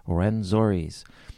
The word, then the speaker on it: Rwenzori: Rwenzori's. How do you pronounce that? R-W-E-N. Rwenzori's. Yeah, with a track called Ewara, Handsome Boy, parts 1 and 2.